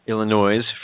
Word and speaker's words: Illinois: Illinois, for those who pronounce the S.